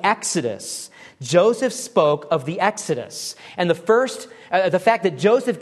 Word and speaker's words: exodus: The Greek word for departure is exodus. That's how you say it in Greek.